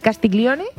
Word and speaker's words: Castiglione: La condesa de Castiglione.